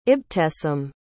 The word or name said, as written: ibtesam